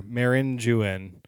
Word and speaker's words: meringue: But this is, Steve, how do you say that? Meringue? – Which one am I looking at here? – This one right here. Meringue. – Oh. Yeah. That sounds right.